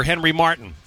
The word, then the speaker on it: Henry Martin: Club America striker Henry Martin, actually is how you pronounce it,